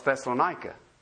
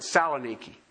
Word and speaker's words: Thessaloniki: During the Kosovo problem, they had a peace meeting in a city called Thessaloniki. You know that city. You call it Thessalonica. Okay? Some will pronounce it Saloniki. Same place.